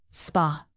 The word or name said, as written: spa